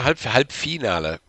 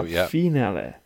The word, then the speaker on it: Halbfinale: Yeah, Halbfinale. So Halbfinale.